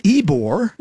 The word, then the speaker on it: Ybor: So, but we're back with Bill Radtke with Ybore, and it's spelled different than it sounds, Y-B-O-R. And if you didn't if you didn't hear why it's called Ybore, you can always catch us on your favorite podcast platform anytime you like.